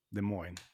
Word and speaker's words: Des Moines: Eh, Slipknot i alla fall, det är då alltså ett amerikanskt metalband från Des Moines. Des Moines. Des Moines.